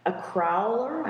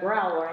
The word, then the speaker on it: crowler: Maybe we'll invest in a machine that can make a crowler. I'm not mispronouncing that. Most people go growler. I'm like, no, it's a crowler, right. Like a can and a growler put together.